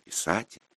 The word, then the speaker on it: писать: В режиме «писать» — ударение на «а».